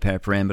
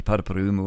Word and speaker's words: Paraparaumu: we call it Paraparam, but it's actually supposed to be Paraparaumu or something like that, Paraparaumu, and Waikanae.